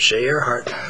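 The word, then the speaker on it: Shaye Areheart: We'll be discussing Lee's new book, The Bright Forever, published by Shea Earhart Books. It's now available in your bookstores. And first of all, Lee, did I pronounce the publisher correctly? – That's right, Shea Earhart.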